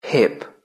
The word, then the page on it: hip: British English